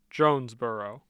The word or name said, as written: Jonesboro